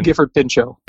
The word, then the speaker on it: Gifford Pinchot: Gifford Pinchot.